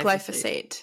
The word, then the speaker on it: glyphosate: The the, the main ingredient in Roundup, glyphosate. – glyphosate.